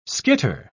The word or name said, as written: skitter